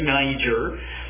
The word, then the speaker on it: Niger: There were in Antioch and the church that was there, prophets and teachers, Barnabas, there he is yet again, Simeon, who was called Niger, the way we pronounce it today, Niger, Lucius of Cyrene, that's northern Africa, and Manaen, who had been brought up with Herod the Tetrarch.